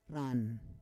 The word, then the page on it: ran: Roon